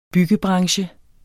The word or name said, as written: byggebranche